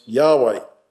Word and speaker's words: Yahweh: And in the Hebrew, that is the word pronounced Yahweh.